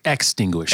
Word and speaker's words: extinguish: And so I think I love that the, the mindset of our faith is not only to protect us, but to distinguish it. Am I saying that word right? Ex- extinguish. Extinguish it. I don't know why I keep saying that wrong.